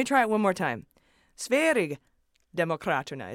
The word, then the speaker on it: Sverigedemokraterna: So I tried again with Sverigedemokraterna, and now you've all come back saying, no, soft G like a Y sound.